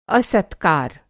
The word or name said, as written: असत्कार